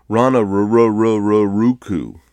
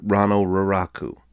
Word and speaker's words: Rano Raraku: The locals make fun of how tourists can never say it right. I had a guide once say, in an American accent, Rana Raraku.